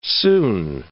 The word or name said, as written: soon